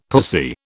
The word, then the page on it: pussy: British English